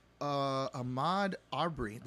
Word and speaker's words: Ahmaud Arbery: Also, the killing of Ahmaud Arbery.